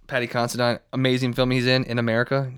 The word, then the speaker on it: Considine: But Paddy Considine, or Considine, I don't know how you say his name, but he's Considine.